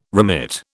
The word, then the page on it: remit: British English